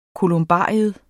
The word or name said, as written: kolumbarium